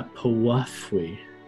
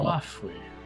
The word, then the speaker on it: piwafwi: And in Undercommon, it's called a Powafwy. Of fire resistance. – How do I spell that? – P-I-W-A-F-W-I. – P-I-W-A-P-I-F-W-Y-I. Oh, yeah. Cool. – Powafwy.